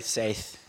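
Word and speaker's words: Saiz: Henry Saith. – Saith. I've always thought it was Saith. – Well, it's like Ibiza. A Z is a TH.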